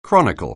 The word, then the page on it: chronicle: anglais